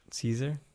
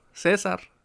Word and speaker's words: Cesar: Caesar? – Caesar. Yeah. Cesar. – Cesar, that's right. – Cesar. – So Americans are pronouncing it wrong, too.